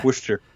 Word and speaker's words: Worcester: Worcester. Yeah, Worcester.